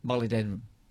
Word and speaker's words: molybdenum: He said, the element is pronounced mol-ib-den-um. – Yeah, molybdenum. – Molybdenum. – Yes, like I like denim.